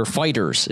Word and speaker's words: FighterZ: Or Fighters is how they want it pronounced.